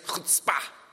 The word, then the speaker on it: chutzpah: Chutzpah. That's actually the right way to pronounce it. I listened to a whole podcast by Malcolm Gladwell. It's not chutzpah. It's chutzpah.